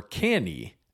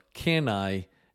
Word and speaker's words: Cannae: It seems that the two most accepted pronunciations are can I, as in can I borrow a pen, or canny, as in the canny fox. It appears that the pronunciation of canae is the Latin pronunciation, but the anglicized pronunciation, meaning in English, is candy.